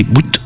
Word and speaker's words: Bout: Ça s'écrit comme le bout, mais ça, le T se prononce.